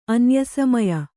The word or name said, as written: ಅನ್ಯಸಮಯ